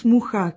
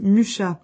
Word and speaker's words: Mucha: entre la mairie de Prague et les héritiers d'Alfons Mucha, que les Français prononcent Mucha.